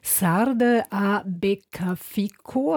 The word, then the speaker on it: Sarde a Beccafico: Sarde a Beccafico, habe ich das richtig ausgesprochen? – Richtig.